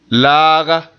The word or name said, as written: laaga